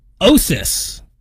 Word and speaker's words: OSIS: Up next, OSIS. They pronounce it like the end of diagnosis.